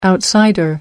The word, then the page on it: outsider: American English